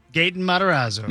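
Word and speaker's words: Gaten Matarazzo: How do you say it? – Oh, I have no idea. Matarazzo? – Gatton? – I think you said it right. – Gatton. – Yeah. – Matarazzo. – Gatton Matarazzo.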